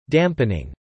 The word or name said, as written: dampening